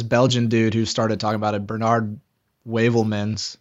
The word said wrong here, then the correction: Heuvelmans: Yeah, Heuvelmans.